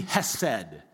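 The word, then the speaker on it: hesed (חסד): And the reason I want you to pay attention to that word is because the Hebrew term behind it is a word we would pronounce it, our best effort to say it would be hesed.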